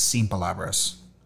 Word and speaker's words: sin palabras: So at Denmark, when we won, I put it on the menu, and the proper way to say it is sin palabras, no words.